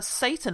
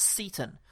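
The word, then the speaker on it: Seyton: It's uh, Satan again, and it's actually pronounced Seton.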